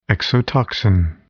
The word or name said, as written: exotoxin